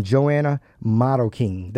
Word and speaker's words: Joanna Marroquin: I have Lee Franklin, and Joanna Marroquin.